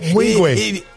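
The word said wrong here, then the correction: Nwigwe: Mwigwe. N-W is Mwigwe.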